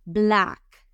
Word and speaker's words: black: La otra, black black. Es fácil. La clave aquí es que pronuncien la seca final fuerte.